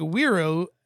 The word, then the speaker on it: Wero: Real quick, Wero. Am I saying that right? – Yeah.